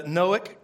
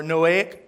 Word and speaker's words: Noahic: We are in the Noahic covenant or Noahic covenant. Depends on who you talk to is how it's said.